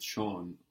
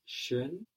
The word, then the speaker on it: schön: So that's schön, beautiful, beautifully, not schon already. Both words are very common. They pronounce it beautifully. – Sie sprechen es schön aus.